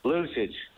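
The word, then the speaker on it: Lučić: Lucic.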